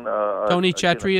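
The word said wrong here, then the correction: Tony Chachere's: is that okay? – What is it? – Tony Chetri? – Oh, Tony Chacheries, yeah. Tony, that's a good one. – Say it right.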